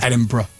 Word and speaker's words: Edinburgh: Americans come over and pronounce it Edinburgh instead of Edinburgh.